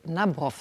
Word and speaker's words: Nabrow: Und interessant, in Ihrer neuen Rolle als neuer Kommissar, da heißen Sie Philipp Nabrow, so wird das ausgesprochen, ist ja auch ein Vater-Tochter-Verhältnis-Thema.